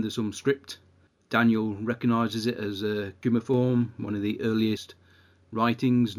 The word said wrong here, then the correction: cuneiform: Yeah, also it's actually pronounced cuneiform.